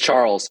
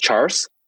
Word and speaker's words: Charles: and my name is Charles. And so when I was a kid, my parents actually pronounced my name as Charles.